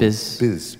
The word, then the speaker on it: Bismol: Say it the way it should be said. Pepto, what did I say? – What? Bismol? – No, say only the first syllable. Pepto... – Biz. Are you implying that you're implying that Bismol stands for bismuth? – I don't have to imply.